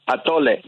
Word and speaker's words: atole: Atole.